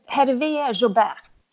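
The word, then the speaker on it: Hervé Jaubert: Hervé Jambert. Och Hervé Jobert. – Ja, det var bättre.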